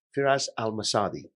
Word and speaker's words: Firas Al Msaddi: So without further ado, I want to introduce to you Firas al-Msaddi.